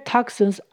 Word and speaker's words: toxins: 请留意 x 在这里不是念格子，它变成 x 的声音了。 k 会跟前面的 to 一起念成 talk，那 s 跟后面的 ins 念成 sens。 Toxins，toxins。 Out of the body，也就是身体外面，所以 clear toxins out of the body 就是指把毒素排出体外。